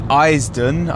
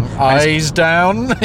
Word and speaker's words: Eisden: And it's Eisden, I think it might be pronounced. – Eisden.